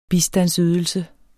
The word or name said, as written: bistandsydelse